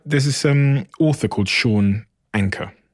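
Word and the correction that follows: Achor: Oh, Sean Aker, yeah, from Harvard. – Butcher his name. – Yeah, Aker.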